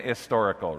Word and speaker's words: historical: Christianity is a historical faith, or like I like to say, an historical, right? I've always grown up not pronouncing that H.